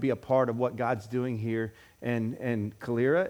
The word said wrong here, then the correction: Calera: Is that how you pronounce it? Am I saying it right? Okay. No. Calera. Yeah, I heard you say that. And I was like, great. Now I've heard the correct way of saying that.